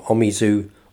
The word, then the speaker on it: Omizu: That's Omizu or Omizu, O-M-I-Z-U it's spelt.